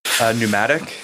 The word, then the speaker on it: pneumatic: What's a word that where the P is silent before an N? – Pneumatic. – Triangle. – Nice work. Go ahead. It's definitely silent, like pneumatic.